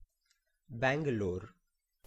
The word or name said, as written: Bangalore